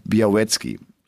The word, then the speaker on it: Białecki: Aber auch andere sagen, nein, der wird Biawetski ausgesprochen. Also das L wohl nicht, sondern wie so ein W und dann halt Biawetski. Und äh,